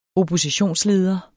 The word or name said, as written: oppositionsleder